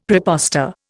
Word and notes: prepostor: Indian

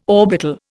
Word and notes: orbital: Indian

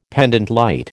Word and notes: pendente lite: American English

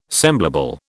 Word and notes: semblable: British English

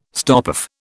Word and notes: stopoff: British English

